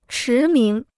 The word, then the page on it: 驰名: chí míng